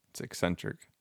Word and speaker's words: eccentric: That's not how you say that. It's eccentric. – Eccentric, eccentric.